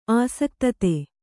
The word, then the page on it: ಆಸಕ್ತತೆ: āsaktate